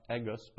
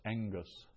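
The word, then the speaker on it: engus (ἐγγύς): Now the last line there, it's a double gamma, which we pronounce not egus, but engus. Double gamma is pronounced as an NG.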